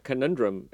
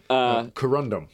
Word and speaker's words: Corundum: And he also, you know, Conundrum. No, is that Corundum? – Corundum.